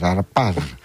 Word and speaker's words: Αρπάζ: Και ο Γενικός Διευθυντής της ομάδας Ισραηλινών που έφεραν το λογισμικό στην Ελλάδα, ο Μέρομπ Χαρπάζ. – Μάλιστα. – Τι Χαρπάζ, Αρπάζ. – Αρπάζ. Έτσι προφέρεται, Αρπάζ.